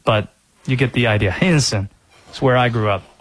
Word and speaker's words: Henderson: Do the people in Henderson, North Carolina, pronounce it that way? They do not. It is two syllables the way that people from Henderson pronounce it. Henson.